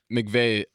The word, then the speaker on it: McVay: McVay, is how you say his name, correct? – I believe so, yeah.